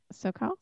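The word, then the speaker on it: Su'Kal: SoCal? – Yes.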